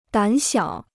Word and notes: 胆小: dǎn xiǎo

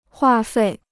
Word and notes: 话费: huà fèi

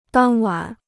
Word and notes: 当晚: dàng wǎn

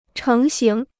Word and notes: 成型: chéng xíng

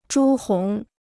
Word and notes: 朱红: zhū hóng

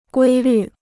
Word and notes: 规律: guī lǜ

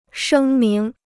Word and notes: 声明: shēng míng

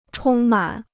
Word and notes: 充满: chōng mǎn

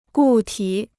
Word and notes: 固体: gù tǐ

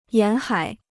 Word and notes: 沿海: yán hǎi

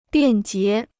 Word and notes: 便捷: biàn jié

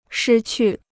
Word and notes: 失去: shī qù

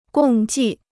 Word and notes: 共计: gòng jì

